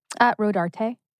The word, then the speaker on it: Rodarte: Rodart or Dior? – Rodarte. – Rodarte, sorry. – Rodarte. – Rodarte. I always said it wrong.